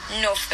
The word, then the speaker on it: Knauff: K-N-A-U-F-F. You should pronounce as Nuff, because normally K is always silent in like KN names.